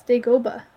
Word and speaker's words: Dagobah: I think it's Dagobah.